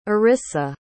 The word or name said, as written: arissa